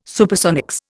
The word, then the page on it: Supersonics: Indian